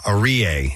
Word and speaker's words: Aryeh: Arie is how you say his name, I believe. It's spelled A-R-Y-E-H.